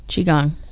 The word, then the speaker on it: Qigong: This Saturday, January 24th at 1 and 3 p.m., we have Intro to Tai Chi with Tom Teague of Skyland's Qigong and Tai Chi Qigong. – Studio. Qigong? – Qigong. – Qigong? – Qigong.